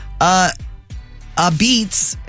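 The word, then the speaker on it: apizza: A beets, it's spelled a pizza, but you pronounce it a beets, is a chewy thin crust pizza typically cooked in coal-fired ovens.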